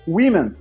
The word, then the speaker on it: women: Você escreve F-I-S-H. É fácil, não é? Sim. Aí você tem uma palavra que é women. Women, você escreve W-O M-E-N. Por que, que, tem um O e você fala o I, igual fish? – É, entendeu?